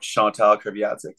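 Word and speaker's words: Chantal Kreviazuk: You told me the story probably 15 years ago, maybe, about Chantal Kreviazic. Is that how you pronounce yes.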